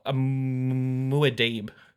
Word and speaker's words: Muad'Dib: Oh, Muad'Dib.